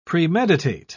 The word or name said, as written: premeditate